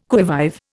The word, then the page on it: qui vive: Indian